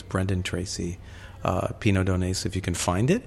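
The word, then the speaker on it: Pineau d'Aunis: It's called Pinot Donais. – Right. – And it's spelled, and I'm going to spell it so people can find it, P-I-N-E-A-U, apostrophe, right. – A-U-N-I-S? – Yeah.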